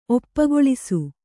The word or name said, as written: ಒಪ್ಪುಗೊಳಿಸು